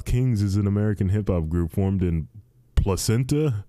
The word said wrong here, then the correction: Placentia: Orange County. How do you pronounce that? Placentia, Orange County, California.